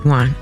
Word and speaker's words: yuan: Yuan.